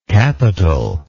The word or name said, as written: capitalness